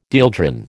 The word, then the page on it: dieldrin: American English